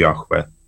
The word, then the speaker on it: Яхве: Да, єврейською мовою це звучить як Яхве.